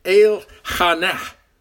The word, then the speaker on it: Elkanah: Now in Hebrew, you pronounce his name El-Hanah.